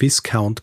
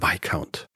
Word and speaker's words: Viscount: Und äh, schließlich habe ich auch noch von einem Viscount gesprochen, ist natürlich der Viscount.